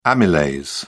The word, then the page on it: amylase: British English